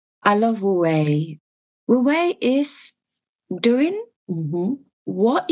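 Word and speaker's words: wu wei (無為): One of the things that I noticed that you speak of is this principle of wu wei. Am I saying that right? – Yeah, wu wei.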